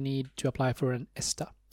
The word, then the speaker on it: ESTA: It is specific countries that are part of the ESTA visa waiver program. That's the way to pronounce it.